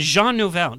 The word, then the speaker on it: Jean Nouvel: and Jean Nouvel. Did I say that right? – Yeah.